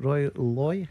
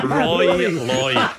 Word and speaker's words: Rory Loy: Roy, is it Roy Loy? How do you pronounce that? – Rory Loy. Goodness me.